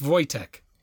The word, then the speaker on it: Wojtek: It's spelled really weird. It's spelled W-O-J-T-E-K. – Yeah, I came across this bear in my studies. – Oh, did you? Okay. – Yeah. – Yeah. It was pronounced Wojtek.